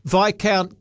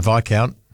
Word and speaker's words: Viscount: Was my first experience with the silent S was the Viscount.